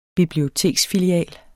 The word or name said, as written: biblioteksfilial